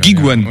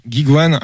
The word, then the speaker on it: GigaOne: Alors en fait, gigouane, ça c'est le terme un peu français, mais c'est gigouane. Ah, c'est moi qui le prononce mal depuis tout à l'heure. C'était pas le seul. Hein. Gigwan. – Ouais, ok, Gigwan. Alors en français, on va dire Gigwan parce qu'en fait, il y, a un jeu de mots.